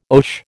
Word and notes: oche: American English

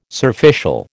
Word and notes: surficial: American English

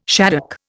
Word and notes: shaddock: Indian

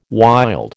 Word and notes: wild: American English

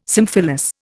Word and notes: symphyllous: Indian

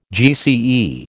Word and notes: GCE: American English

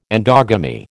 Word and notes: endogamy: American English

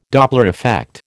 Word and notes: Doppler effect: American English

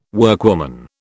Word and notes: workwoman: British English

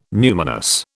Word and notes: numinous: British English

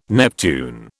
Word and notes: Neptune: British English

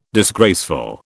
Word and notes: disgraceful: British English

